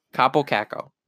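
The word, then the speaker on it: Kaapo Kakko: Capo Caco. – Is that really how you say his name? – It is actually how you say his name.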